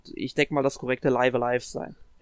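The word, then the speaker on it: Live A Live: aber es müsste, also die offizielle Aussprache müsste tatsächlich Live Alive sein, weil das Spiel im Japanischen auch Raibu Araibu geschrieben wird und danach kann man eigentlich immer gehen,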